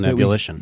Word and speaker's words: Ebullition: On Ebullition.